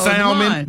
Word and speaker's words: salmon: That's salmon. That's salmon. – Salmon has that, well, that L is supposed to be silent, ain't it? – Kind of, sort of.